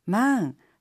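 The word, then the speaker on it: マン: 하이, 망, 응, 남성을 뜻하는 영어, 맨의 일본어식 발음인데요. 모음 차이가 있으니 주의해주세요.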